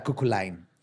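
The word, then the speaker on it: Cú Chulainn: Pero una de las hazañas más famosas de Fion fue su derrota del gigante Cuculain, que se escribe Seu Chulain. – Pero sí, busqué. Cuchulain. – Se pronuncia Cuculain. Cuculain.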